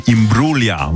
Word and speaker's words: Imbruglia: Alors, si on veut prononcer à l'italienne, on dira plutôt Nathalie Imbruglia. Voilà, le G ne se prononce pas, le G est silencieux, comme on dit.